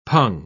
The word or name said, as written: pung